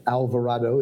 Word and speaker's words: Alvarado: Alvarado is A L V A R A D O dot com.